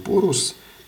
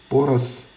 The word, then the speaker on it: Порус: И сериал э, «Порус», «Порос», «Порош», по-всякому можно это произносить э, на индийский манер.